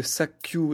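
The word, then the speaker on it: Sakyu (砂丘): Donc euh, bon, au niveau de la prononciation, c'est pas très logique euh, de faire Suna plus Oka qui fait Sakyu, mais en tout cas, au niveau signification, quand vous associez colline plus sable, ça fait dune de sable.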